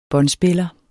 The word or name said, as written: båndspiller